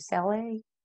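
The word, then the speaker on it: Saleh: How do you say last name? Sale? – Yeah. But again, the Jets job is way more intriguing and way more exciting than the Philadelphia Eagles job.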